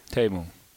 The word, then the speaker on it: Teemu: Good to see you, although I'll start by correcting you. It's Teemu. – What did I say? – Teemu. – I got to get the A in there? – Yeah. There's no A, but it's pronounced Teemu.